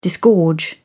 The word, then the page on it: disgorge: British English